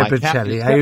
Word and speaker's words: Bocelli: Andrea Bocelli.